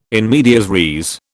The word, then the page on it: in medias res: British English